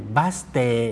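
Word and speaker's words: บัส เต (バス停): บัสเตบัสเตคำนี้ออกเสียงช้าๆชัดๆว่าบะสึเทอีนะครับตรงเทอีออกเสียงรวบยาวเท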